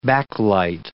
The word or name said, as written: backlight